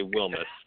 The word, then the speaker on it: Wilmes: It's actually Wilmes.